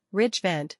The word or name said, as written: Ridge Vent